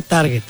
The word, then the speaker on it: target: Se dice target. Sí, a ver, aquí está el target. No, pendejo, es target. En inglés se pronuncia target.